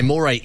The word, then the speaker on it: Moray: A moray eel is a type of eel. So when you're pronouncing her name, you would say moray because it is like the eel.